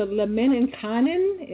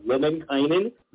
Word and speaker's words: Lemminkainen: Your latest book, hopefully I'm pronouncing this correctly, The Heir of the Lemminkainen. Am I close or what? – You are very close, and I'll give you major points for that because it's a difficult word, the heir of Lemminkainen, so very close.